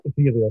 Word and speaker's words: epithelial: Epithelial cells. Epithelial cells.